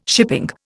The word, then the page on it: shipping: Indian